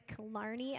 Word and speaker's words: Killarney: If you're friends with us, Calvary Orlando on Facebook, you may have seen this come across your feed, but we're partnering with Killarney Elementary. Did I say that right? Okay. – Yes. – Killarney Elementary.